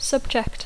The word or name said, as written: subject